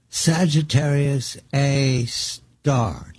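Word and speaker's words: Sagittarius A*: Sagittarius A star. Did I say it correctly? – Yes, that's correct. It's A with an asterisk, but it's pronounced A star.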